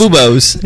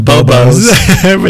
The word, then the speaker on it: bubos: Bubos. Yeah. What? How were we supposed to say it? – I think it's supposed to be pronounced bubos, but we kept calling it bobos, which is so much cuter.